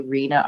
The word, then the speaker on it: Reena: Rina, R-E-E-N-A, High Society Supper Club.